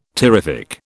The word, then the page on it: terrific: British English